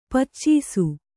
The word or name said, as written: ಪಾಚಿಸು